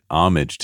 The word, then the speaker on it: homage: I think I said that right. Homage. – You did. – You see that email we got that said I'd been saying homage instead of homage. So I'm trying to say homage.